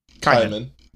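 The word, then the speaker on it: Caiman: Kaiman. – Okay. Cuz that's what I was wondering about whenever I was watching it. It was like, how did they pronounce it? – Yeah, yeah, same as the lizard.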